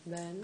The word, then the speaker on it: Bön: Bön. – Bön. Se escribe Bön. – Se escribe Bön. – Sí. It's spelled B-O-N, but it's actually sí. Bön.